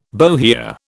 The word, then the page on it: bohea: British English